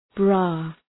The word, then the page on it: bra: brɑ: